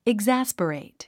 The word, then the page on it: exasperate: igzǽspərèit; イグザスペレイト